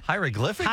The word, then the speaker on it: hieroglyphics: Maybe a corn shuck could be the piece that you could eventually It's like hydroglyphics. How do you pronounce that word? Hydroglyphics? – Hieroglyphics? – Hieroglyphics? Say it again. – Huh? – Say the word. – Hieroglyphics? – Hieroglyphics on the wall.